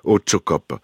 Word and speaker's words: Otsokop: Autchocop, alors sachez, si vous n'êtes pas basque comme moi, qu'Autcho s'écrit O-T-S-O-K-O-P. Autchocop.